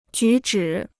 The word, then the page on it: 举止: jǔ zhǐ